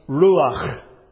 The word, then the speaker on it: ruach: Now, for example, in the Old Testament, there's a Hebrew word which is pronounced ruach. Sounds like you're going to spit. That's how you say the word. In English, you would spell it R-U-A-C-H. Ruach.